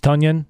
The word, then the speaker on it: Tonyan: Tonyan. – Yeah, actually, it's like Funyan. It's Tonyan, not Tonyan. Thank you. – Yeah, it was for his first 3 years in the NFL, it was Tonyan.